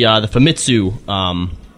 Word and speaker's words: Famitsu: So the first bunch of information that we're gonna garble up here comes from the Famitsu interview. – Did we say that right, Nika? – Yes, said it right, even though it's still spelled wrong on the outline.